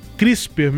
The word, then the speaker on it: CRISPR: Pronuncia-se CRISPR mesmo, CRISPR-Cas9.